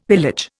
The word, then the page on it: pillage: Indian